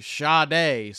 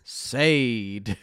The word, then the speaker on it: Sade: So, of course, you know it's Sade.